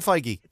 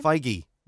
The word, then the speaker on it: Feige: Kevin Feige. – Kevin Feige. Yes. How do you say his name? – Feige is the way they say it in the interviews Feige.